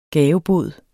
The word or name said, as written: gavebod